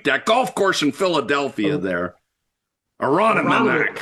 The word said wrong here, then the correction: Aronimink: Aronimink. – Say it again. – Aronimink.